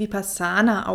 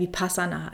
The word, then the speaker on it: Vipassana: Und in der ursprünglichen äh, Sprache, dem Tali, wird es auch wie Vipassana ausgesprochen, aber im Deutschen ähm, ja, sagen wir auch gerne Vipassana. Also ist alles erlaubt.